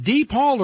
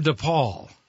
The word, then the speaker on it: DePaul: How do you like your last name pronounced? Is it DePaul or DePaul? – I just say DePaul, because my grandmother always said just to say DePaul.